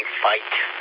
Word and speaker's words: Feit: And that's a pretty popular brand now, that F-E-I-T, Fight. That's the way they pronounce it.